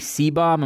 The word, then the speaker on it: Seebohm: is Emily Seebohm, am I pronouncing that right, of Australia, yep.